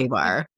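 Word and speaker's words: KA-BAR: It is either Kabar or K-bar. It's some sort of combat knife and a sheath. – K-bar. – K-bar? Okay.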